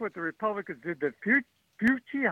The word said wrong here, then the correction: Fauci: how do you say that name? I think that was Mr. Fauci. I mean, during my time, he's Mr. Fauci.